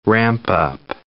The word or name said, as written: ramp-up